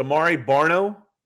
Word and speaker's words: Amare Barno: Um, but uh, um, is it Amare Barno? from Texas, uh, Virginia from Tech. – Virginia Tech. Did I pronounce his name right? I think so.